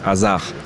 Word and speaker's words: Hazard: Tu apellido es Eden Hazard, pero aquí es Hazard, pero en Bélgica es Hazard, con Z. Eso es mucho más complicado.